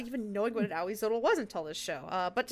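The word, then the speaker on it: Ahuizotl: This is Auizotl. Spelt very interestingly. It's A-H-U-I-Z-O-T-L.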